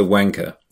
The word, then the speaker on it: wanker: Да, то есть, во-первых, произношение – это wanker.